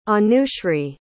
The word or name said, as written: anushri